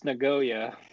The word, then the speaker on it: Nagoya: And it's pronounced Nagoya.